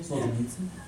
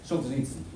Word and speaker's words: Solzhenitsyn: Solzhenitsyn? – Yeah, Solzhenitsyn.